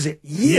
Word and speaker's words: gyro: No, the correct pronunciation in Greece, I'm told, is gyro.